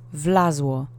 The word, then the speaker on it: wlazło: The first word is wlazło, which means he, she, it crawled or climbed, depending on the context. Accent is on the first syllable.